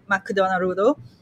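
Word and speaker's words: Makudonarudo (マクドナルド): So I wanted to create my version of the McDonald's, which they would call it Makudonarudo. That's how they pronounce it.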